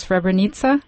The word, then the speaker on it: Srebrenica: I'll try. It's Srebrenica? – You said it very well.